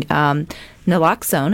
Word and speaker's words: naloxone: I know other things within your programs is giving out or distributing naloxone, am I saying that correctly? Yes.